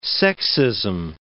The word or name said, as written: sexism